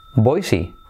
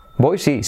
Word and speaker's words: Boise: ¿Cómo se pronuncia Boise? Se pronuncia Boise. Boise, sí, claro, claro que voy, Boise.